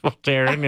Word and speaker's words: Voltaren: Is that how you say it? – Yeah, I think Voltaren cream. – Voltaren, yeah.